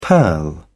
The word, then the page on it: purl: British English